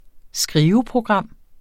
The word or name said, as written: skriveprogram